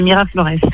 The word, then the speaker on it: Miraflores: J'espère que j'ai bien prononcé hein, le nom du restaurant Mira Flores, hein, c'est bien ça. Hein. Tout à fait, Mira Flores.